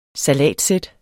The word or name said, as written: salatsæt